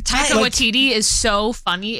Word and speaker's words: Taika Waititi: And Rita Ora and her husband, Takiti. – I love him. Sorry. Taika Waititi. – Mm-hmm. – Taika Waititi.